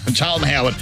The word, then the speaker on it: Charlton Howard: You mispronounced the first time. Charlton Howard.